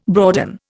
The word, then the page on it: broaden: Indian